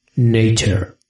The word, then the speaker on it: Nature: Nature. – Muy bien, ¿eh? Cada día la pronuncias mejor. – Me ha salido la T un poco regular, ¿eh? – Bueno, no pasa nada.